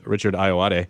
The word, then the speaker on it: Richard Ayoade: Sitting to Jonah Ray's immediate right is Richard Ayoade.